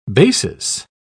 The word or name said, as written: basis